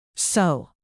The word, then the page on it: sow: gardening